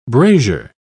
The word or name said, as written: brazier